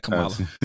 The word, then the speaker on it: Kamala: Is it Kamala or Kamala? I been saying it wrong? – I think it's Kamala Harris. – Oh, my bad, Kamala. Sorry.